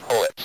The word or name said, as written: poets